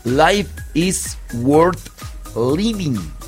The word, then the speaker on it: Life is Worth Living: Life is Worth Living. Sí, sí lo dije, sí dije bien la pronunciación, porque las que no son maestras de inglés luego me corrigen.